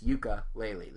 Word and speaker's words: Yooka-Laylee: So, Ukulele is Yooka-Laylee. That's Yooka-Laylee, the name of the two main characters, who were also revealed in tandem with the Kickstarter kicking off.